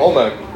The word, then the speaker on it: Olmec: And by the way, I was drawing on that Olmec. I hope I'm pronouncing that right. I think Olmec. – Yeah. – You got it.